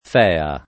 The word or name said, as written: Fea